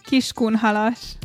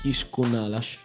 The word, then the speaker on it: Kiskunhalas: Posso risentire la pronuncia? – Kiskunhalas. – Kiskunhalas. – Sì.